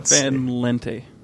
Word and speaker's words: Van Lente: Let me Fred Van Lente. L-E-N-T-E. – Van, yeah, Lente. Yeah, that would make sense. V-A-N-L-E-N-T-E.